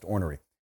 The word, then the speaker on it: ornery: That is exactly how the word is pronounced, ornery.